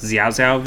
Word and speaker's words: Xiao Xiao: Actually, there were a couple of games of this, but did you guys ever watch or interact with the, I believe it's pronounced Xiao Xiao videos? X-I-A-O, X-I-A-O? – Maybe.